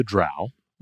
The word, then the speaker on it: drow: The central plot revolves around the drow, rhymes with brow, not crow,